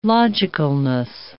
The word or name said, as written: logicalness